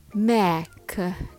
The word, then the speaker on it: mechanism: All together, mech, niz, Try saying it a few times. Mechanism. Mechanism. Make sure the first syllable is strong and the rest flow smoothly.